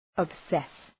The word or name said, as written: obsess